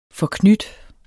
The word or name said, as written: forknyt